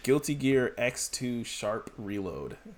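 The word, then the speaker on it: Guilty Gear X2 #Reload: So, number 35, Guilty Gear X2 Sharp Reload. And that is how you say that.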